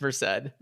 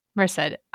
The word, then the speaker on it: Merced: I need you to look up Isabella's name. – Merced. But, I believe it's Merced. – Merced.